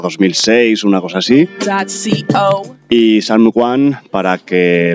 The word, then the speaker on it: Psalm One: Una MC de la ciudad de Chicago llamada Salm One. P-S-A-L-M-O-N-E. Para quien quiera obviar esta pronunciación defectuosa.